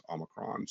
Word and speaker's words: Omicron: So again, a lot of mispronunciation of Omicron and stuff like that, but the proper pronunciation of it is Omicron. So we had seen a few other variants between Delta and what we've got right now.